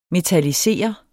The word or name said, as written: metallisere